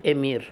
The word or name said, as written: Emir